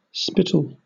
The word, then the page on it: spittle: British English